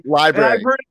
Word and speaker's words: LBRY: Library. Yeah. – Library or library? – Just library. Just library. Like library, like a place with books.